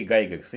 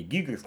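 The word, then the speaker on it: Gygax: А, Дэйв Арнисон и Гэри Гайгекс, или Гэри Гигекс, там по-разному фамилию его транслитируют.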